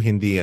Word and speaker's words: Hindi: But I don't know much about Urdu, Hindu, or Punjabi. Am I pronouncing those correctly? – Urdu, Hindi, and Punjabi. Yeah.